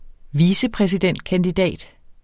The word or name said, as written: vicepræsidentkandidat